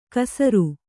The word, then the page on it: ಕಸುರ್: kasur